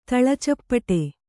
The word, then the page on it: ತಳಚಪ್ಪಟೆ: Kannada